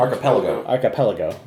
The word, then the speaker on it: archipelago: Archipelago. – I always forget how to pronounce that. Archipelago. – You had it right. Just had the wrong emphasis on the wrong syllable.